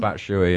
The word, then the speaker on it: Batshuayi: Batshuayi or something? – Batshuayi, yeah.